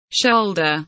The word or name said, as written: shoulder